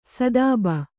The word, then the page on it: ಸ್ತೋಭ: stōbha